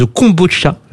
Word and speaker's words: kombucha: De kombucha, c'est comme ça qu'on prononce.